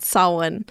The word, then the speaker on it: Samhain: I think I called it Samhain. That's how it's spelled, but I think it's called Samhain because the Celtic pronunciations of things are all weird.